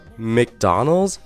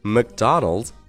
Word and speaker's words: McDonald's: 哎，那我刚刚说了这个 McDonald's 或者是 McDonald's 都是可以的。但是据我观察了解啊，一般美国人更喜欢去读成 McDonald's，也就是一个短元音的呃这样的发音的 McDonald's。